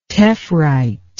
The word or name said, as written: tephrite